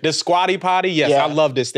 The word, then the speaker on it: Squatty Potty: Now, my favorite in-home appliance now is a Scooty Pooty. You know what this is? – You mean the squatty potty? – The squatty potty. – Yeah. Am I saying it wrong? Oh, yeah.